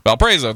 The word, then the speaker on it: Valparaiso: Well, this was actually in November, so this is after their very first game against Valsparo, or how I always say the college name. Valparaiso? – Valparaiso, thank you. I always say it wrong.